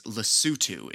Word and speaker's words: Lesotho: The country we'll be learning about this week is Lesotho, is how it is pronounced.